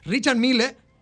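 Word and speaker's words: Richard Mille: Se trata del UP01, producido por el especialista suizo Richard Miller. Richard Miller Richard Mill, eh, se escribe que terminado en E, ¿verdad? Eh, pero es así como se pronuncia.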